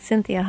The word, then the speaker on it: Cinthia: Certainly like my Facebook page at Cinthia Hiett, Inc.